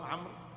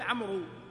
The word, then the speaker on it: عمرو: تكتب ليفرق بينها وبين عمر، بينها وبين عمر بس، فهي واو للتمييز فقط، لكنها لا تلفظ أبدا. فلا يصح أن تقول عمرو، تقول عمرو، جاء عمرو ورأيت عمرا ومررت بعمر.